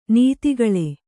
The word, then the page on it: ನಿಧಿಗೊಳು: nidhigoḷu